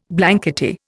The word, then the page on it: blankety: Indian